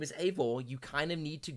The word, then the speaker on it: Eivor: Ivor. – Oh, Eivor. It is Eivor. – Eivor. Okay. – It's Eivor.